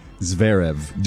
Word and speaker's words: Zverev: Zverev.